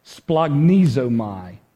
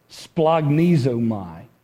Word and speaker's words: Splagnizomai: Anybody want to try to pronounce the Greek word there? Splagnizomai. That's how you say that word. Splagnizomai.